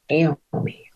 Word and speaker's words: Naomi: Naomi. Long A, long O. – Oh, you got actually, every vowel in her name is long.